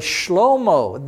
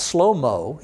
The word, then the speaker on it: Shlomo: So while you may think that the biblical name is called Solomon, it's actually Shlomo, the SH sound. Okay, not slow mo, he was a pretty fast guy. Not slow mo, Shlomo, S H L O M O, you see. That's how it's pronounced.